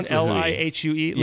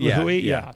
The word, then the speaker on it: Lihue: In Lihue, is that how you pronounce the name of the town? Lihue. Lihue.